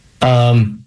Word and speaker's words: quercetin: Quercetin.